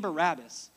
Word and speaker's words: Bar-Abba: Well, the name Barabbas in the Aramaic actually would have been pronounced Bar-Abba. Bar-Abba. Bar-Abba. Bar meant son of.